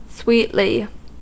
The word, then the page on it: sweetly: American English